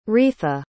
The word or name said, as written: reatha